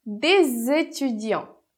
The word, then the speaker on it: des étudiants: Então, a gente vai fazer a liaison produzindo o som de Z entre D e étudiant. Então, des étudiants, des étudiants, que é uns estudantes.